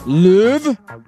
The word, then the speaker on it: Louvre: Is it the Louvre Museum in Paris? Is that how you pronounce it? – Yeah.